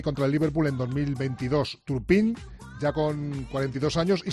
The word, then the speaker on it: Turpin: ¿Lo digo bien, Pedro Martín? – Bueno, tú sabrás. Sí, Turpin, que de momento es el árbitro con el que el Madrid ha conseguido mejores resultados en la historia de la Copa Europa.